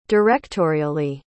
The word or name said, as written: directorially